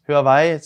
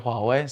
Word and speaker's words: Huawei: Men eh, nei, jeg, jeg tror um, ehm, Huawei, som, som, er den norske uttalelsen, eller Huawei, som er den mer in internasjonale, er for de som er veldig eventyrlysten.